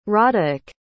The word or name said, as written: roderic